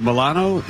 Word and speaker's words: Milano: Alyssa Milano is 47. Is that pronounced like the cookie? – Yes.